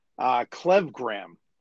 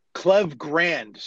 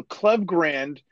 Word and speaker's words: Klevgrand: But they were saying Klevgram. – Klevgram? – Klevgrand. Sorry, that's the way you pronounce it. So Klevgrand. – Like ND, not like Gram. – Yeah, Andy, like grand larceny, grand theft auto.